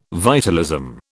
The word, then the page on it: vitalism: British English